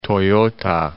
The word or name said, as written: Toyota